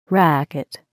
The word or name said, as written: racquet